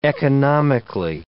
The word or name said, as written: economically